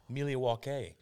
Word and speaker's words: Milwaukee: He's like, actually, it's pronounced Milia Waukee.